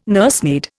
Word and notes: nursemaid: Indian

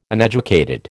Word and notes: uneducated: American English

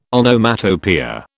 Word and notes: Onomatopoeia: British English

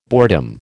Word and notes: boredom: American English

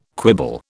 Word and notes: quibble: British English

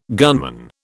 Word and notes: gunman: British English

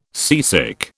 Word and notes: seasick: British English

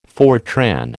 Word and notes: Fortran: American English

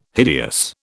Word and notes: hideous: British English

